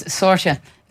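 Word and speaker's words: Sorcha: I think particularly, you know, the Finbar's girls up front, Orla Callahan and Sorka McCartan are just so efficient at linking up, and I think I was told I was saying her name wrong all this time. It's Sorsha, not Sorka.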